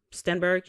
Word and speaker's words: Stenberg: Amanda Sternberg, um, Amanda, um, let me look it up. Hunger Games Black actress. What's this young lady's name? Amanda Stenberg. That's how you pronounce it, right? Stenberg? Yeah, that's what I'm reading.